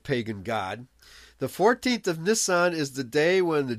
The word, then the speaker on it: Nisan: The 14th of Nisan, or I guess some people pronounce it Nissan. – Nisan.